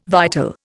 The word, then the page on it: vital: Indian